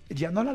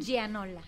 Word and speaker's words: Gianola: Este, primero ella, Yadira Yaniola. Gianola, eh, ¿lo dije bien? – Gianola.